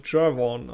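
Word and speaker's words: Gervon: Gehen wir einfach mal von der schönen amerikanischen Aussprache Jervon aus.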